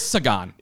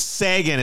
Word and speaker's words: Sagan: No, it is Sagan. – It's Sagan. It's Carl Sagan. – Sagan.